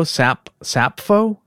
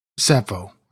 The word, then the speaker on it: Sappho: The HMS Sappho, or S-A-P-P-H-O, Sappho? How would you say that? – Sappho, I think. – Sappho, okay.